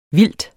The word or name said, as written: VILDTET